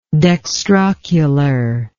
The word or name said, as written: dextrocularity